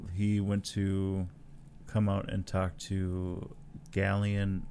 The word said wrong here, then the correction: Galen: Erso. – Galen. – Galen. Thank you.